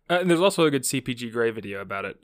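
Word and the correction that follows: CGP Grey: I did say it right that time, right? – No. – CGP Gray? – Yes.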